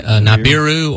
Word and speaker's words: Nibiru: Well, I did find out this week that Planet X, before it got the name of, how do you say that, Nairobi? – Oh, that's probably one way they say it, but I say it Nibiru or Nibiru. It just depends on how you want to pronounce it, brother.